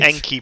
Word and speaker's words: Enki: Enki? It's E-N-K-I, Dave. That's Enki. – What did you just call me? – Yeah, I just didn't want to say it. – Razer right. Enki Pro Chair.